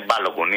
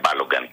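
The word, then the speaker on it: Balogun: Συνάδελφοι, είναι Μπάλογκουν, είναι παιδί Νιγηριανών μεταναστών. Δεν είναι Μπάλογκαν, να το λέμε σωστά πώς προφέρεται.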